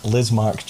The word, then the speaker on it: Lizmark: What did you say? El Lizark? Did you say Lizmark Junior? – Lizmark. That's I've just butchered that man's name, Lizmark Junior.